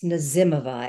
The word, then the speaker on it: Nazimova: is it Nazimova? – It's Nazimova, and everybody has a different way of pronouncing it.